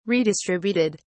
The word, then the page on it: redistributed: British English